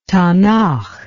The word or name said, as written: tanach